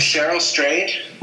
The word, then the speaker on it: Cheryl Strayed: It is based on a novel by, I hope I'm saying this right, is it Cheryl Strayed or Cheryl Strayed? – Yep. – Cheryl Strayed. – Cheryl Strayed, yep.